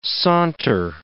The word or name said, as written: saunter